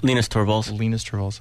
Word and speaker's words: Torvalds: Linus Torvalds.